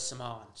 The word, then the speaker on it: Semaan: All right, it's spelled S-E-M-A-A-N. So, Same street. – PG, keep it PG, boys. Saman, we had the argument in the office, so we found out that it was Saman.